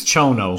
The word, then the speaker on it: Chono: Is it Chono? Do you pronounce it Chono or Kono? I think it's Chono. Chono.